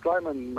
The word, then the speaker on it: Sleiman: Sleiman. My name is Sleiman Nejim. – Sleiman. Yeah. Sleiman. Okay, Sleiman. And you pronounce it Nejim? – Nejim, N-E-J-I-M.